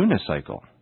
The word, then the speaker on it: unicycle: And some words spelled with the letter U in the beginning, such as unicycle, U-N-I-C-Y-C-L-E,use the article a, because the first sound is the y sound, y.